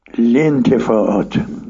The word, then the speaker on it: الانتفاعات: مثلا بله و سایر الانتفاعات، اینجا من میگم و سایر الانتفاعات، چون که این دو تا الف ما قبل لام و ما بعد لام هر دو همزه وصله. قاعده نحویش این است که همزه وصل در شکل و شمایل نوشته میشه ولی تلفظ نمیشه.